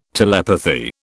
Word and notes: telepathy: British English

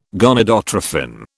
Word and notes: gonadotrophin: British English

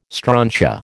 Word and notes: strontia: American English

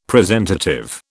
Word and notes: presentative: British English

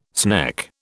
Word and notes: sneck: British English

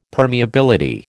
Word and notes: permeability: American English

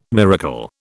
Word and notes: miracle: British English

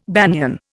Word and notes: banian: Indian

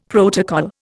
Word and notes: protocol: Indian

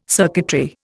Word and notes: circuitry: Indian

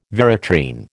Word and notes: veratrine: American English

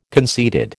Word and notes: conceited: American English

